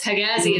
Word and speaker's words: Hegazi: And we're the only country that pronounces the letter G like G. Every other country, it's like a J. So it's Hegazi is like, everyone knows I'm Egyptian, just like off the bat. Otherwise, it would be spelled with like a J or pronounced that way, like Hijazi.